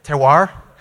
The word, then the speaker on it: terroir: Terroir.